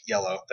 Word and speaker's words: Iello: Iello? – It's IELLO. It's French, it's yellow.